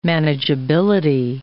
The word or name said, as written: manageability